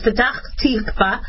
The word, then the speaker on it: Petach Tikva: My father would be mad if I mispronounce it, but it's Petach Tikva.